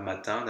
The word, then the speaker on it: matin: Matin here also has a silent n, so you could be tempted with this logic to do the liaison. But in this case, we don't, because we normally don't do a liaison after a noun in the singular, which mm-hmm.